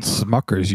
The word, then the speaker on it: Smucker's: People keep calling me Schmuckers. – And it's called Smuckers, you dope. I was going to call you Schmuckers. – You are a smuck. – Did I say it wrong? – Yeah, you did say it wrong.